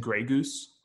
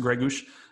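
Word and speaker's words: Greguš: every time John Champion said Gregus, it sounded to me like he was saying Grey Goose, like the vodka. – Yes. – Except as Sean Connery. But so you got thirsty, right? – But it's pronounced Gregus.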